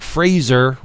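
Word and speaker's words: Frazer: Because when we first met him back in the bullpen days, he said Fraser rhymes with laser.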